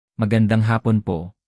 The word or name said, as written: magandang hapon